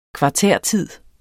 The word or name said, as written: kvartærtid